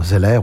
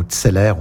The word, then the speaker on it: Zeller: Alors, Zeller ou Tseller, je pense qu'on va le prononcer à la française.